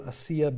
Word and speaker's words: Asiya: In Iran, excuse me, in uh, Pakistan today, there is a lady named Asiya Bibi. You'll see her in just a minute. Her name looks like Asia, but it's pronounced Asiya.